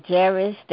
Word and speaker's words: Jairus: The topic of the lesson is Jairus, that's how I pronounce his name, daughter and the bleeding woman.